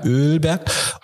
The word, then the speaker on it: Ölberg: Man muss die Betonung aufs Öl legen, ne? Das merke ich. Ja, also ja. Ölberg.